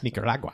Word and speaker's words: Nicaragua: Yes, Nicaragua. – I had to say it correctly. It's just like, it's like jalapeno or piñata.